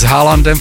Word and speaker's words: Haaland: Přátelé, do kina vtrhnul Spider-Man z Brusu Nový den, Tom Holland, mně se líbilo v televizi, v americké televizi, kdy s Haalandem fotbalistou, protože ono se to vyslovuje Haaland, tak já bych mu vlastně měl říct Tom Holland, vyslovuje se to stejně a akorát, že každý dělá úplně jiné řemeslo.